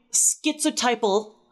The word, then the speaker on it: schizotypal: Or showing schizotypal. I know that's pronounced right.